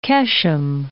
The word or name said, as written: Qeshm